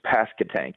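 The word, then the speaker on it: Pasquotank: Well, I know you're a Yankee that lives in the South, and you're still not quite saying it correctly, but you don't accentuate the Q-U really at all. It's Pascatank. It's Pascatank, almost like it's a K. – Okay. – Pascatank. Right. Yeah, it's not Pascatank, but it's Pascatank.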